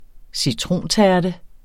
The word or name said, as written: citrontærte